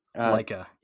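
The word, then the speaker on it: Laika: And it's from Leica. Is that how you say it? Leica. – Leica.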